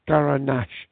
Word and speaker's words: Garnacha: Now, this, of course, is the name that they use in Spain for Grenache. That's G-R-E-N-A-E-H-E, Grenache. And this is pronounced in Spain. It's the same grape, but they call it Garnacha. That's G-A-R-N-A-C-H-A.